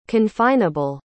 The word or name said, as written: confineable